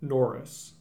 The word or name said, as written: Norris